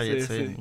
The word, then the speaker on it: Yates: Oui, c'est les frères Yates. – It's a good pronunciation.